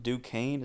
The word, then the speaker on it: Duquesne: Florida State got their win over I think Duquesne is how you pronounce it.